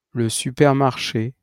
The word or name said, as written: le supermarché